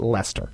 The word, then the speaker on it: Leicester: I would never, sorry to interrupt you there, or sorry to make you pause it on the spot there, I would never have seen that word and thought to pronounce it Lester.